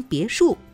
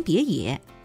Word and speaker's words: 别墅: 第一次开会是在他上班的第四天，他被老板骂了个狗血喷头，起因是他给老板写的演讲稿中有错别字，老板按演讲稿照读不误，将别墅读成别野，结果台下员工有哄堂大笑者，有掩面而笑者，老板气急败坏，开口大骂：笑什么笑，老子读错了，这怪老子吗？